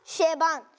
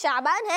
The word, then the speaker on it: شعبان: آپ نے کہا شعبان, شعبان نہیں ہے, اس کا صحیح تلفظ شعبان ہے.